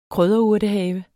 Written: krydderurtehave

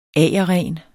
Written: AGERRENE

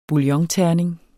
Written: bouillonterning

